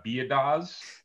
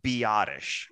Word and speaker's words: Biadasz: So, again, all phonetic, and I appreciate the pronunciation. Mm-hmm. Tyler Biadasz, which, yeah, full disclosure, I don't know if we've ever had anyone sound this out correctly that didn't already know it.